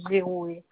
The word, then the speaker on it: Gerohe: Madame Géroué. Ça s'écrit G-E-R-O-H-E.